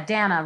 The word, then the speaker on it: Dana: Yeah, Dana rhymes with banana, works great.